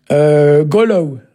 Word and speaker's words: goulou: Golow. Alors, ça s'écrit Goulou, la lumière.